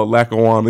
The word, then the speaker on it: Lackawanna: That right there had to be a challenge to be kind of isolated in Lackawacka, Pennsylvania. Did I say that right? – Oh, Lackawanna. This is Lackawanna.